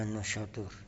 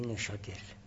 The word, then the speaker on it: نوشادر: بعد از اینکه کلی تموم شد، وارد شدیم بعضی از ملها رو ذکر کردیم، شب و نوشادور، که در فارسی نوشادور تلفظ میشه، در عربی نوشادر، و من نوشادور تلفظ کردم به تلفظ فارسی بود. البته فارسی وابش هم بر میداره، نونش هم غالبا کسره میده، نشادور.